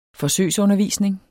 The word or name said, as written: forsøgsundervisning